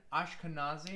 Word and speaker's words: Ashkenazi: Uh, uh, uh is the pronunciation Ashkenazi? Ash, you got nailed it. – Hell yeah. Well done.